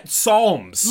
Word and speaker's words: Psalms: Yeah, Psalms. – Literally. – We got the Psalms. – Nigga, that was it. – Bro, why'd they spell that with a P? That is unnecessary.